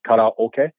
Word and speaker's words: karaoke: Kata means empty. – Okay. – And oke is orchestra. So empty orchestra, karaoke. – Oh. – That's how they pronounce it. So it's not karaoke, first of all.